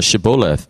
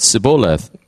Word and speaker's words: Shibboleth: Then they would say to him, 'Say now, Shibboleth.' And he said, 'Sibboleth,' for they could not pronounce it correctly.